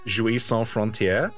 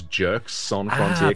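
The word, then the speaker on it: Jeux Sans Frontières: There was a terrible old British game show called Jouis Sans Frontier, Games Without Borders. But if you read it phonetically, it was Jerks Sans Frontier. – There we go. So I'm getting to the heart of it. – J-U-X.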